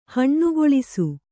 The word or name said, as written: ಹಣ್ಣುಗೊಳಿಸು